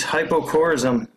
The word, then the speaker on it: hypocorism: What was his hypocorism? Did I say that right? – You did.